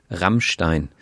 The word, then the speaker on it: Rammstein: Yeah, we say Rammstein. – Rammstein. – But they roll the R, which... – Rammstein. – Which is something we actually don't do anymore.